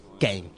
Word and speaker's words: Kane: Estamos acá ya en el piso con Emanuel, Nacho, Mati y con el diputado provincial Kane. Está bien pronunciado, arrancamos.